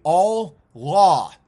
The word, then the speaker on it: Allah: Dude, Allah makes more sense phonetically. All law. Oh, how dare you? It's Jehovah, but you say hey, and you did, dude, at least Allah is all law.